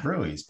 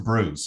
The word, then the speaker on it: brews: I thought like fish and brewies, that would be like fish and chips, but with beer, because it says brewies, but it's not brewies is not. Oh, it's pronounced brews.